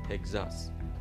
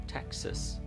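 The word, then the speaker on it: Texas: تگزاس آنچه آنان میگویند تکساس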